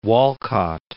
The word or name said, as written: Walcott